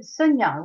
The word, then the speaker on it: Szonja: Alors je ne sais pas prononcer le prénom de votre héroïne, c'est Sonia ? – Sonia, oui, Sonia. – C'est écrit S-Z-O-N-J-A, et là je me suis dit, tu vas forcément te planter.